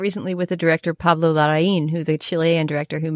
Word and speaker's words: Larraín: And if you did a little bit of research into what the accent means, you could figure out that it's Larraín, right, with that accent on the end.